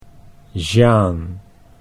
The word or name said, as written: Jeanne